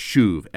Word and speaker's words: shuv: Based on the Mosaic law, based on the law of Deuteronomy, the prophet calls them to repent, in Hebrew it's shuv, S-H-U-V, to shuv. I always like to think mnemonically, so shuv kind of sounds like shove, like if you take S-H-U-V and pronounce it shove, the people are trying to shove or to shuv, the prophets are trying to shove the people back to God.